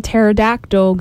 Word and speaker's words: pterodactyl: Okay, so for the first one, Why can't you hear a pterodactyl going to the bathroom? – I don't know. – Because the P is silent.